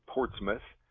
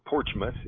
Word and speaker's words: Portsmouth: in South Central Ohio near the town of Portsmouth, or Porchmouth, if you use the local pronunciation, at a little camp, a Boy Scout camp, near the Shawnee State Forest.